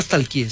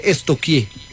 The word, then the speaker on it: stalkear: Porque no se dice nunca stalkeé, se dice estoquié. – ¿Estoquié? Sí. – Pregúntele a la profe, verá. A mí una vez estamos estoqueando. – Sí, estoqueando. Yo, pero se escribe stalkear. Es estoquear.